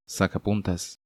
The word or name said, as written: Sacapuntas